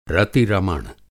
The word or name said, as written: रति-रमण